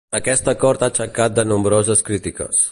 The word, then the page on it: aixecat: catala